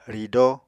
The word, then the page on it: ridóo: Apatani